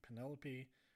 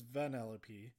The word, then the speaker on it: Vanellope: and at first I thought they were saying Penelope, but then they were not pronouncing a P. Anyway, I'm pretty sure it's Vanellope,